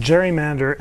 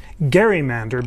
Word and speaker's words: gerrymander: I should say, during our panel at the Constitution Center, I said gerrymander, and someone corrected me and said it should be pronounced gerrymander, because Elbridge Gerry was the originator of this.